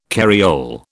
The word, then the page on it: carriole: British English